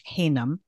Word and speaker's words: Haynam: I have a guest and his name is Josh Haynam.